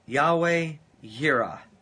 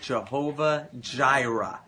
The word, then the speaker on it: Yahweh Yireh: It is Yahweh Yireh. And that is sometimes pronounced Jehovah Jireh.